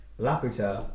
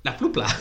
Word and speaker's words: Laputa: You nailed it. You got the name right. – I've never said the name right. – I feel like we had a week of you being like, La Pupla.